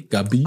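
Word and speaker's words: Gavi: Wie ich heute in meinem Spanischkurs gelernt habe, spricht man das V mit B aus, ne? Alle Vs sind B, Gabi.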